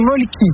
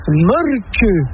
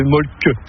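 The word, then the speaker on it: Mölkky: Inventé en Finlande en 1996, ce jeu s'écrit M-O-T-M-A-L-K-K-Y. – Ça s'écrit molki, mais ça se prononce molke. – Le molki. – Plutôt un E qu'un E, mais pas un U. – Ça fait molque.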